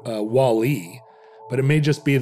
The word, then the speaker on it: WALL-E: I like that you said it right. You said WALL-E. – WALL-E. – Accent on the second syllable.